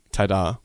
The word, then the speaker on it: Ta-da: Yeah, how did you pronounce that there? – Ta-da. – Yeah, okay, you got it.